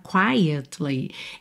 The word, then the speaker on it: quietly: Aqui, olha, na palavra quietly, essa letra E é pronunciada. Perceba que a gente tem quietly, quietly, all right? Olha, vitória da letra E.